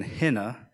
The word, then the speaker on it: hineh: the first one being Hina, and it is spelled H-I-N-E-H, which literally means behold or look.